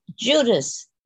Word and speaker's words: Judas: The first day, the name Judas in French is silent, S, you don't pronounce it, you say Judas. So the teacher calls out, Judas.